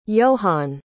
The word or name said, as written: yohann